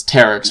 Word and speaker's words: pteryx: Yeah, actually, with I shouldn't be pronouncing the P, it's like pterodactyl, so it's pterix, yeah. But there's a P in front of it.